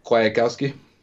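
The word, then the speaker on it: Kwiatkoski: How do you say that Bears linebacker name? Nick Piatkowski? – Kwiatkowski. – Yeah, that's one.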